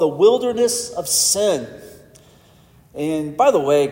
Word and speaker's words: Sin: It just so happens that that Hebrew word is pronounced sin.